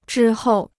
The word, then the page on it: 之后: zhī hòu